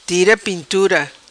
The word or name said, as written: Tira Pintura